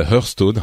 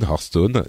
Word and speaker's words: Hearthstone: euh, Hearthstone, Hearthstone, les deux prononciations sont valides,